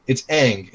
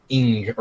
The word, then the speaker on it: Aang: It's Aang. It's not Ing or anything along those lines.